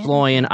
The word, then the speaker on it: Sloyan: And Samantha Sloyan, right? Is that how you say it? – Sloyan.